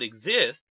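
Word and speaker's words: Xist: Performing that so-called X chromosome inactivation is a special type of RNA called exist, pronounced like exist.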